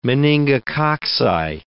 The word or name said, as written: meningococci